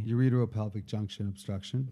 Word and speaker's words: ureteropelvic junction obstruction: Ureteropelvic junction obstruction.